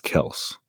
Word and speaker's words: Kelce: Is it Kelce or Kelce? – It's pronounced Kelce. – Whoa. Okay. – I mean, learn something new. Everyone calls him Kelce. – I know. That's why I was like, wow. – Technically, they pronounce it Kelce.